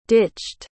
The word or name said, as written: ditched